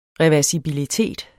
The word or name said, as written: REVERSIBEL